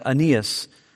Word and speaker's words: Aeneas: Aeneas.